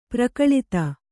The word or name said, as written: ಪರಿಕಲಿತ